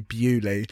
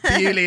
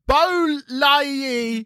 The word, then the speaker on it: Baulieu: Bewley is spelt B-A-U-L-I-E-U. Imagine people like, just like someone being like, and next up we have... Bowley. Yeah, so probably wouldn't work.